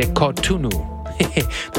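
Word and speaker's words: Cotonou: But that is not the same band as Orchestra Polyrhythm de Kantu, de Kanutu. So I always say that wrong. De Kotunu.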